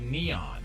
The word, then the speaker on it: N3on: No, it's it's spelled N, the number 3-O-N. N3on. – Yeah, but he goes by Neon.